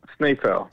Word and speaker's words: Snaefell: All right, and you said there is a bonus track called, how do you pronounce it? Snaefell? Snaefell? – Snaefell.